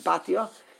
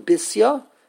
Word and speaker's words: Bisya: but the pronunciation is not the pronunciation that was given to her by her father, or by Divrei Hayamim, but it's the pronunciation that was given to her by Chachmenu Zichronim Levracha. They took the name Bisya, and they have repronounced it, repackaged it with a new pronunciation, Basya, and that's common.